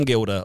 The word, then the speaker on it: Gilder: So, at Tom Gilder on Twitter is probably the easiest place. G-I-L-D-E-R, because some people sometimes spell it with a U, like the old Dutch code.